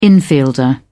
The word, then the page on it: infielder: British English